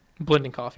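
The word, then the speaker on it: Biletnikoff: Former, how do you say the name? Blintenkoff? – Blintenkoff, yeah.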